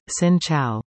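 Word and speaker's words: Xin Chào: The greeting you most often hear in Vietnam is Xin Chao, pronounced Sin Chow.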